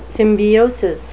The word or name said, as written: symbiosis